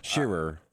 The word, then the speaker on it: Scherer: S-C-H-E-R-E-R. – Yes, sir. – Shearer.